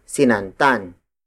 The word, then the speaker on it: sinantan: After kati, we have sinantan. Sinantan. Sinantan. Sinantan is spelled as S-I-N-A-N-T-A-N.